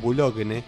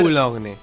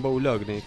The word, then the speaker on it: Boulogne: Bueno, si te lo cortó la DGI, como suele pasar, sobre todo en Boulogne, lo que tenés que hacer... – Boulogne. – En Boulogne, claro, lo dije mal, perdón.